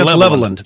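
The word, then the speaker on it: Levelland: It's L-E-V-E-L-L-A-N-D. Level land. – Level land. – But we say Leveland. Yeah. That's the way people pronounce it here, yeah.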